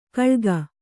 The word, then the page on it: ಕಳ್ಗ: kaḷga